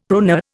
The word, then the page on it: proneur: Indian